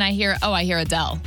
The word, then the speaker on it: Adele: I mean, you see the name Adele, A-D-E-L-E.